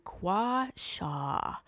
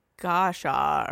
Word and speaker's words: gua sha: I said that wrong for like a year. – How did you say it? – I think I called it gosha, or I said it wrong, and I remember being like, oh, I have been saying that really wrong. Or like guashu, or like I was something totally wrong. So guasha, like,